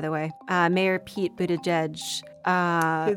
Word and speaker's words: Buttigieg: Boot edge edge, like a boot on your foot, edge at the edge of a table.